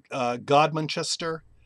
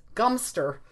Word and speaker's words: Godmanchester: And I'm not sure, you've come across this one, Godmanchester. – Yes. – God Manchester. – Yes. Gumster. – The BBC says it's pronounced Gumster.